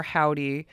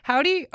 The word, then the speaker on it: Hovvdy: They are Howdy. Howdy are from, and it's spelled H-O-V-V-D-Y, so it's like a stylized W, if you will.